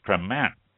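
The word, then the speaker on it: Crémant: Other French regions refer to their bubbly as Cremant. That's spelled C-R-E-M-A-N-T. Or Vin Mousse, I think it's pronounced. V-I-N-S-M-O-U-S-S-E. U-S-S-E-U-X.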